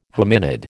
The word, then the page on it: hominid: American English